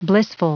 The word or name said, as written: blissful